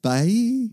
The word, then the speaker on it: Bai: Is that how you say it? B-A-I. – I think bye.